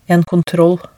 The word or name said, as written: en kontroll